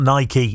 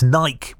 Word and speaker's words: Nike: It's not Nike, it's Nike. We know this.